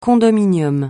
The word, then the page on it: condominium: francais